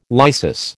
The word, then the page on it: lysis: American English